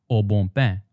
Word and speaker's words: Au Bon Pain: But if you were to pronounce these words the French way, which has strong nasal sounds, then the bon becomes bon, and pain becomes bain. Notice that the nasal vowel sounds are so strong that you don't even hear the N's anymore.